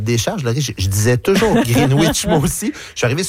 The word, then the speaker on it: Greenwich: Et on ne prononce pas le W. Donc, ça fait un Greenwich.